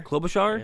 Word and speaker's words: Klobuchar: We have Amy, I've never been able to pronounce her last name, Klobuchar? – Klobuchar, is that it? – Yeah.